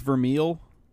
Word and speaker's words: Vermeil: Now, isn't it pronounced Vermeil? – I don't know. I just... – Let's go to whatever. – Google. – Vermeil, I think it is, because... I don't know. The way they say it in Japanese, anyway, it says Burameiru.